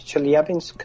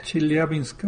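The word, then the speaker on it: Chelyabinsk (Челябинск): ¿Así se pronuncia? – Yo no hablo ruso, pero sí, Chelyabinsk. – Chelyabinsk, ok. Yo pongo el acento en el lugar equivocado. Ok. – No, Chelyabinsk, lo estoy leyendo aquí en ruso, sí, sí está correcta nuestra pronunciación. Yo no soy muy bueno leyendo ruso, pero sí está prácticamente idéntico a la transliteración que se hace al alfabeto latino.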